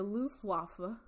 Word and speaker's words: Luftwaffe: I watched Pearl Harbor a lot, and that is how it is said. – I love that. – Luftwaffe.